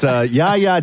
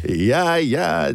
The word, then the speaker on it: Jaja: It's Ya Ya Ding Dong if you want to look it up. And Ya Ya is spelled J-A-J-A, by the way.